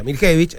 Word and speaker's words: Miljevic: Si Matko Miljevic viviera en Serbia, sería Miljevic. Pero vive bueno. acá y él dice que el apellido se pronuncia Miljevic.